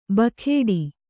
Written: ಬುಗುಡ

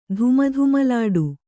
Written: ಧುಮಧುಮಲಾಡು

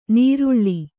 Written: ನರಳು

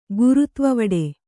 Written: ಗುರುತ್ವವಡೆ

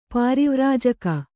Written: ಪರಿವ್ರಾಜಕ

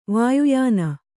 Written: ವಾಯುಯಾನ

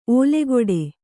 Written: ಓಲೆಗೊಡೆ

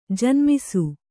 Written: ಜನ್ಮಿಸು

